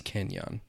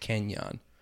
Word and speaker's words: Kenjon: His name is Kenyon. – It's pronounced K-E-N-J-O-N. – No, it's spelled Kenjon. It's pronounced Kenyon.